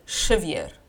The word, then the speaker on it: Xavier: But in Javier. Portuguese, you write it with an X, and you pronounce it Xavier. Yes, it sounds Russian.